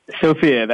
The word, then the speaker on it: Sofia: Sofia, that is correct.